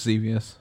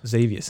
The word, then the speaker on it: Xevious: Arcade Archives, Xevious. – Xevious, that's the one.